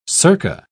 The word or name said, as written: circa